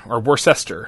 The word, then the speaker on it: Worcester: Worcester, of course, is the proper pronunciation.